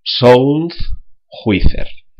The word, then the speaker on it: SoundJuicer: J-U-I-C-E-R. Repetimos el nombre completo del programa, castellanizando la pronunciación. SoundJuicer.